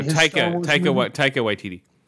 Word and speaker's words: Taika Waititi: And forgive me if I say this wrong, but is it Taika Waititi? – No, Taika Waititi. – Taika Waititi? – Okay.